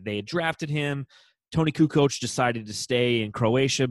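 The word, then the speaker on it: Kukoč: It's Kukoc.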